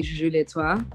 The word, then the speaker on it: Gugulethu: You've got this way of saying your name that's almost like it sounds like you're saying it in French. How do you say that? – Yes. Gugulethu.